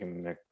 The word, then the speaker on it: McKeown: By Mr. Greg, I think he pronounces it McCown. – Greg McKeown. – Yeah, it's like meow.